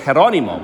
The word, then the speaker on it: Jerónimo: My legal name is Jerónimo, which is Jerome in Spanish. It's spelled like Geronimo.